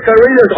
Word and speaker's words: Carrillo: Charlie, it's not Carrillo. – No. – In Carrillo, the L is silent.